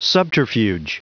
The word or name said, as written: subterfuge